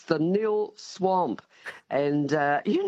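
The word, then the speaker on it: Nhill: The H is silent, and it's N-H-I-L-L, of course, and it's pronounced nil.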